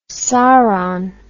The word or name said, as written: saron